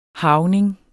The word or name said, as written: harvning